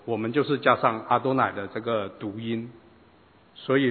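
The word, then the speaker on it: 阿 多 乃 (אֲדֹנָי): 那么这几个原点就告诉他们怎么样去念，加上这几个原点和短线以后，他念作阿多乃。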